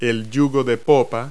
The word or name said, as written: transom